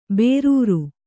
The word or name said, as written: ಬೇರೂರು